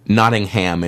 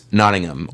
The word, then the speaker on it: Nottingham: You know, it's a silent H, so instead of Nottingham, it's Nottingham, or Rotherham, or Birmingham.